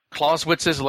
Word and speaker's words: Clausewitz: Clausewitz's legacy, am I pronouncing that properly? – Clausewitz.